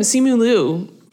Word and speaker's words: Simu Liu: But not even him, Simu Liu.